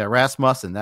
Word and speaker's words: Rasmussen: Yeah, Rasmussen. I guess that's it. If I go Muss instead of Muse, then I'm good.